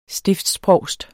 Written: STIFTSPROVSTEN

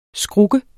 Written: skruk

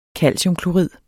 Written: calciumklorid

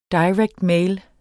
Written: mail

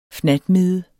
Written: fnatmide

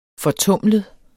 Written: fortumlet

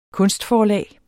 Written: kunstforlag

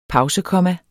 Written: pausekomma

